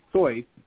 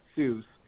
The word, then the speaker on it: Seuss: Now, if you want to be a purist, he's of German extraction. It's actually Seuss, not Seuss, but no one ever pronounced it that way.